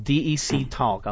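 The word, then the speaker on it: DECtalk: DEC Talk.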